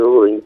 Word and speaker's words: Bodin: Se escribe Juan Bodin, se escribe Bodin, se pronuncia Bodin, ¿no?